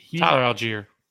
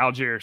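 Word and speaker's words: Allgeier: Allgaier, Algier, whatever you say his name. – Tyler Algier. – Yeah, Algier.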